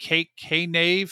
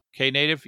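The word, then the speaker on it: Knative: Is it K-Nave? How do you pronounce that? – I thought that? – It was K-Native, no? – K-Native? Yeah, K-Native. The K-Native book author.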